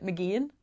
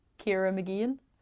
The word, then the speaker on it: Mageean: I pronounce it Mageean, Ciara Mageean. There's no H in it. There's no C in it.